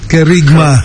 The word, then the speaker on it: Kerigma: Y el retiro para jóvenes adultos de 19 a 35 años de edad, retiro de Kerem, ¿cómo se pronuncia? – Kerem. Kerigma.